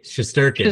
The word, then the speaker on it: Shesterkin: For me, Shesterkin.